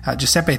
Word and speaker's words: Giuseppe: Giuseppe, thanks for spending a few minutes with us today.